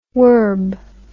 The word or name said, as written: worb